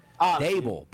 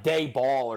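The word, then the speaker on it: Daboll: How do you say it? – Dable. Brian Dable. – He said like Day Ball or something like it was.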